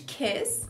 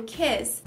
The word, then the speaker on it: kiss: A primeira é que o i do kiss tem o som de e, kiss. Kiss. Não, não é aquele i mais forte, senão vai parecer chave.